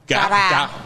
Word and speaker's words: Dara: And your Cambodian name is Dara. – Dara. How do you spell that? – D-A-R-A. – D-A-R-A.